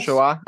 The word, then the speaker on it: Shoah: was just the impact of the Shoah. Did I say it correctly? – Yes. – Shoah.